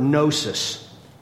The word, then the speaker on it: gnosis: And that Greek word for knowledge is the word gnosis. Gnosis, G-N-O-S-I-S, kind of like a gnu, except gnu is pronounced new, right? The G is silent.